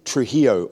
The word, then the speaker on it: Trujillo: That's spelt with a J, T-R-U-J-I-L-L-O, but it's Trujillo because he's a Cuban man.